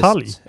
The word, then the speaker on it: Batalj: batalj. Hur... Då ska jag fråga honom. Hur har du tänkt att amerikanarna ska uttala namnet på spelet? Batalj. Batalj. Batalj. Batalj. Som butelj. Batalj.